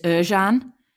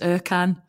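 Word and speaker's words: Ercan: I mean, actually, traditionally, his name is Erjan. But Erjan, yeah. For me, it's always just, it's spelt with a C, so it's Erkan.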